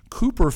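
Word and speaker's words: Cowper: Now, this is how they say it in this. Cooper found refuge in a fervent evangelical Christianity.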